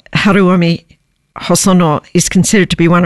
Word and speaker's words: Haruomi Hosono: So I know how to pronounce this guy's name, Haruomi Hosono.